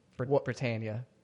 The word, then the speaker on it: Britannia: Full Britannica. That's Britannia. I forgot how you say it.